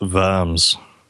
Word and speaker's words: Worms: Oh, and you do know that there's a town in Germany called Worms, right? It's spelled Worms, but it's pronounced Worms.